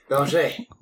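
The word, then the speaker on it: Bélanger: Gotta say it in French. – Belanger.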